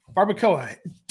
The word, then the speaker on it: barbacoa: How do you pronounce that meat that he put there? Barbacoa? – Barbacoa. Don't you guys have Chipotle? – Yeah, but I've never gotten the barbacoa.